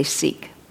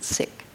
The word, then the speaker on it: Sikh: But I think the strongest experience was what's called Langar, L-A-N-G-A-R. And this was the service provided by the Sikh community. We often say Sikh, but they pronounce it Sikh, who served a free vegan lunch, excellent lunch, every day to as many people as wanted it.